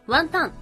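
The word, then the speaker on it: 雲呑: で、その中の一つに雲を飲むと書いてワンタンと読むものがあります。この間横浜の中華街に行った時もね、たくさんその雲を飲むっていう字のワンタンっていう漢字を見ました。これはスープに浮かぶワンタンが雲が浮かんでいるように見える。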